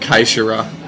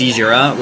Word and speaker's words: Caesura: C-A-E-S-U-R-A. – Yeah, Seishura. – I was saying it Kaishura, and I was terribly, terribly wrong. So I'm sorry to all you people out there. – There's a lot of people. – Yeah. – Nobody can pronounce our band name. It's supposed to be pronounced Seishura, which sounds a lot like seizure, and we just didn't want to...